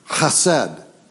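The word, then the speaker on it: chesed (חסד): And the way that you would pronounce it in Hebrew is, it's chesed. Chesed is the Hebrew word for mercy.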